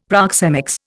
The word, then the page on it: proxemics: Indian